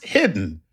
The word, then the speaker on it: Hidde: Chuck, Hitty is pronounced hidden without the N.